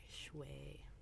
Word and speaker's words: Schwey: S-C-H-W-E-Y. Shui.